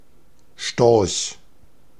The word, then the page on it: Storch: [ʃtɔʁç]